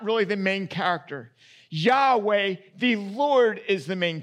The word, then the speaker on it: Yahweh: Best that we can tell, it's pronounced Yahweh. Often in our translations, it looks like capital L, capital O, capital R, capital D. This often stands in for the personal name of God. And as best as we can tell, in Hebrew, Hebrew from right to left, that is most likely pronounced Yahweh.